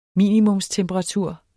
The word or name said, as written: minimumstemperatur